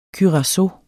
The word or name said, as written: curacao